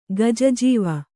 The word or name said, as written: ಗಜಾಜೀವ